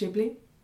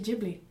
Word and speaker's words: Ghibli: Studio Ghibli films. So I recently learned that it's pronounced Ghibli. You know, Princess Mononoke, Spirited Away. It's actually Ghibli, even though Ghibli sounds a lot more.